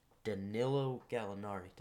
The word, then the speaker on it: Danilo Gallinari: And then there is, I'm going to try my best at pronouncing this name, Danilo Gallinari. Did I say that right? Yeah, I said that right.